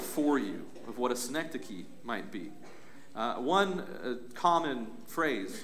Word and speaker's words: synecdoche: This word is pronounced synecdoche.